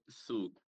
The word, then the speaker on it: Sioux: A família da língua da Lakota Dakota, eu acho que o nome oficial é Siouan, alguma coisa assim, mas é aquela que se escreve Sioux. Então eu acho que dificilmente alguém vai olhar Sioux e ler Su.